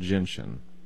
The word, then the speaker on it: gentian: Why don't we try herbal bitters or what's called gentian? – Okay, spell that word. – G-E-N-T-I... A-N, I think, gentian.